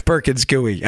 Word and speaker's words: Perkins Coie: Perkins Coie.